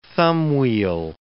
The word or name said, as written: thumbwheel